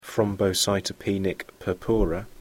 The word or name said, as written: thrombocytopenia purpura